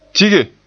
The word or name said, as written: tige